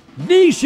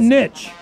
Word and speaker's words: niche: Niche is correct. – Yeah. – Not niche.